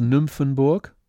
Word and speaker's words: Nymphenburg: And I think one important point here is the pronunciation of Y, Upsilon in German. Y in English, it's the Greek letter. Then it's pronounced in German, U, like U, U umlaut. So we have Schloss Nymphenburg, der Olympiapark, der Olympiaturm, die Glyptothek.